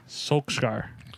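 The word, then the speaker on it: Solskjær: There's a reason why Ryan Giggs is not the manager, and there's a reason why Ole Gunnar, how do you pronounce his last name? – Solskjaer.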